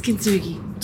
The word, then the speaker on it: Kintsugi: Yes, it's Kintsugi.